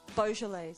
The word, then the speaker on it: Beaujolais: It's Beaujolais? How do you say this? Sorry, I don't know. – Beaujolais.